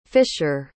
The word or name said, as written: fischer